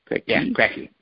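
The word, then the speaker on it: Krackey: Is that K-R-A-C-K-L-Y? Clara Hull Crackley. You mentioned her. – Crackley. – So that's K-R-A-C-K-E-Y? – Cracky. Mm-hmm. – What does she have? – Don't impose the E, just Cracky. Yeah, Cracky, just Cracky. – Oh, you mean C? It's K-R-A-C-K-Y, no E-Y.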